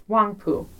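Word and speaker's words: Huangpu: Wangpu. It's just Wangpu.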